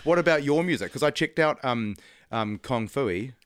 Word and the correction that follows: Kung Fu: I said that right, didn't I? – Kung Fu.